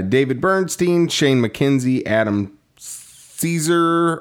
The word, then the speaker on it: Cesare: I heard him on the horror show with Brian Keene, and he said that he says it's Caesar, even though everyone else says it's Cesare.